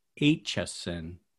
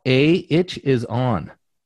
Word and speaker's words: Aitchison: And it is, in fact, Brother Scotty Aitchison. – Spelt A itch is on. So if you think about it that way, A itch is on, you'll never spell it wrong. But, and then it's Aitchison.